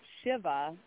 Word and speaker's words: Shiva: And it's actually pronounced Shiva, and it is Hindu, and you've got it right on the hit the nail on the head there.